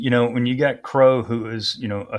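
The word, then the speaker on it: Crowe: I call him Crow because of Admiral Crow. That's how you pronounce his name, former CNO and former Chairman of Joint Chiefs of Staff.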